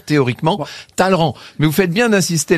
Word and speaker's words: Talleyrand: Vous, vous dites toujours Talleyrand. Moi, je dis Talleyrand. Qu'est-ce qu'on doit dire? – Ça s'écrit Talleyrand, mais ça se prononce Talleyrand.